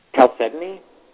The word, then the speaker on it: chalcedony: Talk to me about, and I'm never sure I pronounce this right, chalcedony? – Chalcedony? – Chalcedony.